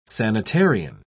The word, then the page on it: sanitarian: British English